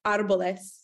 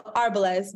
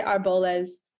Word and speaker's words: Arboles: It's trees in Spanish, which is pronounced, the Spanish pronunciation is Arboles. So the accent's on the A. So Arboles, but some people say Arboles. I don't really care either way, but like if we're being...